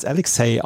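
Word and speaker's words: Aleks-Cee: Und er hatte erst den Künstlernamen Alex C, also Alex A-L-E-K-S, Bindestrich C-E-E, aber das wurde immer als Alexei ausgesprochen, deswegen hat er sich umbenannt in Alexei.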